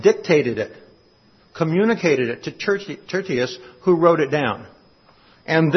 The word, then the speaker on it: Tertius: In Romans chapter 16, verse 22, there's a verse and it says this, I, Tertius, T-E-R-T-I-U-S, some pronounce it Tertius, Tertius, who write this letter, greet you in the Lord.